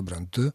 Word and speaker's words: Бронте: По поводу фамилии Шарлотта Бронте, я всегда, в общем, тоже произносил именно так, даже не Бронте, а Бронте, пытаясь как-то передать то, как это должно звучать. Конечно, это галлицизм. Конечно, так и должна эта фамилия произноситься.